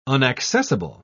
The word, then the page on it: unaccessible: British English